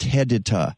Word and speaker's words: HEDTA: And it's spelled H-E-D-T-A, but pronounced hedita. Go to a garden center and see if they have an iron hedita product.